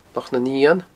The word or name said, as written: Loch nan Eun